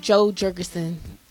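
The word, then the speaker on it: Jo Jorgensen: But the Libertarian the Libertarian candidate is, would you say her name was Joy Jorgensen? – Joe Jorgensen.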